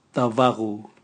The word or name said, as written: tavagu